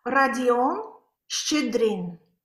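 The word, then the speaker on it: Rodion Shchedrin: Radion Shchedrin.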